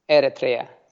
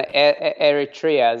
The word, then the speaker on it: Eritrea: Eritrea. – See, I still am going to say it wrong. – No, no, Eritrea is the way correct way of pronouncing it.